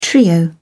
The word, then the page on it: trio: British English